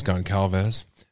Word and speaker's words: Goncalves: This is interesting to me because it's been reported that Kylie Goncalves, or Gonclaves, I've heard it pronounced either way. You'll see in a minute. But I think it's Goncalves.